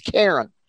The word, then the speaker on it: Charon: His name is Charon. It's kind of funny. You pronounce his name like the girl's name, but it's spelled C-H-A-R-O-N.